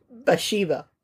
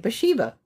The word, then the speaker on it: Bathsheba: Bathsheba. How do we say that? – That is how you say it. Bathsheba.